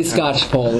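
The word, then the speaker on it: Skocpol: But given this Scotch bowl, is that the pronunciation? – Yeah, say it is Scotch bowl. – So much pronunciation comes with this. – It is it's definitely Scotch. Definitely Scotch pole is how you pronounce.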